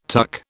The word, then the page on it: tuck: British English